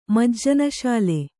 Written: ಮಜ್ಜನಸಾಲೆ

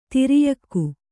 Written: ಧೌರೇಯಕ

